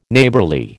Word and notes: Neighbourly: American English

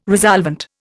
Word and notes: resolvent: Indian

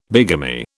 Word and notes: bigamy: British English

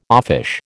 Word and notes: offish: American English

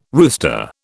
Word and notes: rooster: British English

